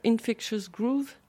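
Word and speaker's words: Infectious Grooves: Oui, moi je suis arrivée avec un petit Infectious Groove.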